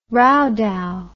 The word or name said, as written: rowdow